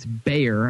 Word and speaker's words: Bayer: And, by the way, Scarborough did say Bayer. It's Bayer. I mean, you know, he wasn't mistaking it for different. He just pronounced it a little bit weird.